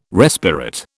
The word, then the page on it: respirate: British English